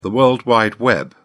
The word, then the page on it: world wide web: British English